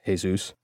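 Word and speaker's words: Jesús: That was quite a while ago, but it's hard to forget a 6'6" detective named Jesus, even if it is pronounced Jesus.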